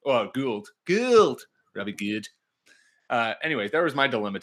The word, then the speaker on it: Gould: I'm being very Wisconsin, so I'm pronouncing the first three letters like Gouda and then just take the LD Gould.